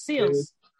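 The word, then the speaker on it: Seales: because Amanda Seale's character, I'm probably butchering her last name, Cielis. – No, it is. No, it's Seals.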